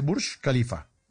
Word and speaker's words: Burj Khalifa: O sea, lo que uno pronunciaría mal como Burj Khalifa, se dice Burj Khalifa.